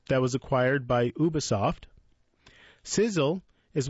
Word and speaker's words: SZL: Called SZL, pronounced Sizzle, but spelled SZL,